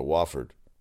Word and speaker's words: Wofford: Since falling to BC in January 2009, they lost to Wofford.